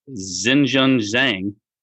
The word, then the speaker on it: Xinjun Zhang: 170th is Mr. Xinjun Zhang. – And great job there. – I totally butchered that. – We're going to take you that. You got that. Yeah, I think you nailed that.